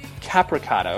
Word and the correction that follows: Capicotto: okay. – Capicotto. – Capicotto, yeah. Okay.